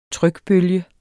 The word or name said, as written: trykbølge